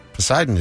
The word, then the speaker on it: Poseidon: Poseidon is correct. – A not silent P that I feel like should be silent.